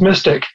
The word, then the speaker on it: MSTIC: and then MSTIC, pronounced as MYSTIC.